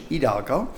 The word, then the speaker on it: Hidalgo: And we went to a place called Old Hidalgo Pump House, probably better Spanish pronunciation, Hidalgo.